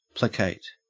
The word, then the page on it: placate: Australian English